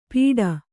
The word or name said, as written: ಪಿಟ